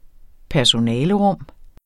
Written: personalerum

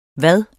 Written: hvad

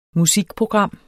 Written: musikprogram

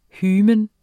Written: hymen

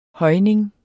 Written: højning

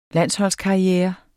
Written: landsholdskarriere

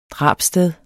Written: drabssted

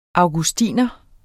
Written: augustiner

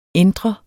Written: entre

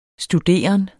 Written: studeren

